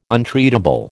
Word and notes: untreatable: American English

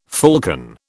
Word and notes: falcon: British English

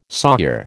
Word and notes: sawyer: American English

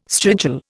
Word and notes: strigil: Indian

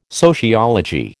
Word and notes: sociology: American English

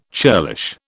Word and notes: churlish: British English

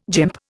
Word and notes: gymp: Indian

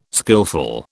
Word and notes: skillful: British English

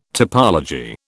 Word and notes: topology: British English

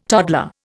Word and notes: toddler: Indian

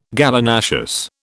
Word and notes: Gallinaceous: British English

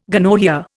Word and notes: gonorrhoea: Indian

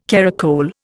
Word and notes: caracole: Indian

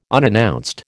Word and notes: unannounced: American English

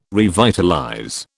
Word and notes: revitalize: British English

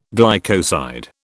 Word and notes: glycoside: British English

